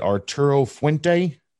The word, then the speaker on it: Arturo Fuente: But your, what, how do you say this? Is it Arturo Fuente? – Yeah. – Special.